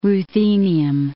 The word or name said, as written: ruthenium